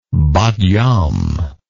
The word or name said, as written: bat yam